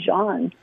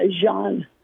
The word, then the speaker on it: Jean: So it's actually Jean, like French pronunciation. That was what he decided to do. So, yeah, Jean.